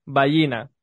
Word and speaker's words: Vallina: que cuando le preguntaba cómo se pronunciaba su, su apellido, me dijo, Ballina, como gallina, pero con U en vez de G.